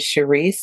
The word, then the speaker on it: Sherise: So as you said, I am Sherise Anderson. My name is Sherise. It's spelled S-H-E-R-I-S-E, Rise.